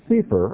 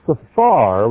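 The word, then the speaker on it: sefer (ספר): Now, you can pronounce those three letters in various ways. If you pronounce these words, this word as sefer or sefer, it means a book. Okay? You've heard of a sefer Torah, means a book of Torah. Right? You've all heard this word sefer. If it's pronounced sefar with an A sound, same spelling, just different pronunciation, it means number.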